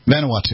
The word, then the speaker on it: Vanuatu: Vanuatu, yes.